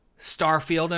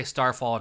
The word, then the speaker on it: Starfield: Starfield and Redfall. I said it right. I always want to say Starfall and Redfield.